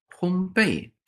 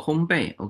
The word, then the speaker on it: 烘焙: 烘焙焙烘焙哈哦，烘焙，我们读音不准啊。嗯哦，烘焙 OK。